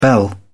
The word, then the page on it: bell: British English